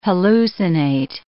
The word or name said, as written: hallucinate